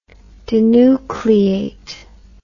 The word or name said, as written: denucleate